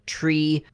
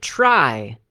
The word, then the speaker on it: trie: Tri also sometimes, I guess, historically pronounced tree, but that is rather confusing because tree sounds like tree, so they're pronouncing it try, because there's no other word that sounds like try. T-R-I-E. Of course, in English, I'm being sarcastic, in English it's T-R-Y.